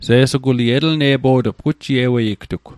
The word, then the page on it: Se'suguli: Mi'gmaq